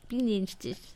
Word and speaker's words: Bienenstich: Bienenstich, the bee sting. How do I say that? Do you say it? – Bienenstich.